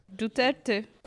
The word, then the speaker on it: Duterte: Duterte.